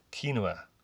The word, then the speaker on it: quinoa: I learned how to say this. Check it out. Quinoa.